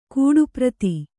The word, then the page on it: ಕೂಡುಪ್ರತಿ: kūḍu prati